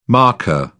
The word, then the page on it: markka: British English